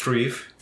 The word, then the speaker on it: dryf: And then the Anglo-Saxon D-R-Y-F, which is pronounced trief. – Trief. – Because the F is a V sound and the Y is your Drief the Wagon on the pilgrimage.